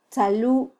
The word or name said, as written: tzalu